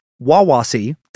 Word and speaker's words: Wawasee: He now takes his son with him to Lake Wawasee, that's how you say it, Indiana's biggest natural lake, totally contained in Indiana.